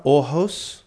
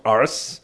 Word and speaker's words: Aarhus: But so far, Aarhus, as it's pronounced. Not arse, has been good to us.